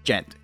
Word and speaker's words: djent: It's djent. D-J-E-N-T. Djent. It's pronounced djent. It's spelled with a D, but you just don't pronounce the D, you know?